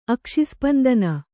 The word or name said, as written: ಅಕ್ಷಿಸ್ಪಂದನ